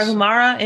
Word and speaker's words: Tarahumara: How do you say that? Tarahumara? Does that sound good? – Sounds good. Yes. Tarahumara Indian.